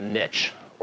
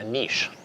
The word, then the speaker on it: niche: And so, very selfishly, we said, let's go find a niche, or as we say in Canada, a niche, which is the proper way to say it.